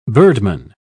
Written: birdman

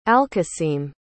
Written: alkasim

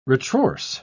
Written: retrorse

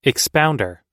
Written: expounder

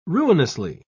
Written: ruinously

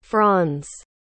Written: franz

